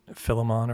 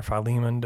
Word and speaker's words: Philemon: And what we read in Philemon or Philemon, depending on how you want to pronounce it, mm-hmm.